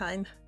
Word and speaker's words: Hein: Välkommen till Babys podcast, Anette Hein. – Tack. – Uttalar jag ditt efternamn rätt nu? – Ja, det var helt rätt.